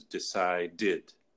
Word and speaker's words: decided: And it's the same thing with decide. Past tense is decided. – Mm-hmm. Because the ending sound D. – Right. – And we add the syllable.